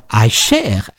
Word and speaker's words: Eicher: We listen to an excerpt from The Exception, interpreted by Stéphane Eicher, or Eicher as the French pronounce it.